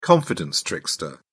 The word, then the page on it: confidence trickster: British English